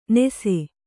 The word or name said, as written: ನೆಸೆ